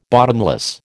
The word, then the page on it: bottomless: American English